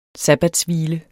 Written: sabbatshvile

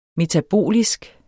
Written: metabolisk

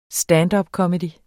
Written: standupcomedy

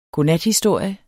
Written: godnathistorie

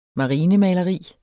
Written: marinemaleri

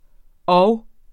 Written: orv

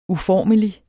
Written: uformelig

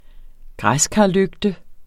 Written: græskarlygte

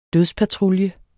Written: dødspatrulje

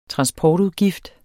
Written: transportudgift

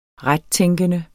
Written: rettænkende

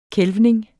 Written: kælvning

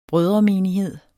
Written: brødremenighed

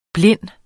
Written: blind

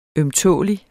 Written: ømtålelig

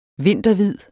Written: vinterhvid

